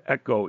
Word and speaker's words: echo: But the word having here in this verse, having, is the word echo, E-C-H-O, long O.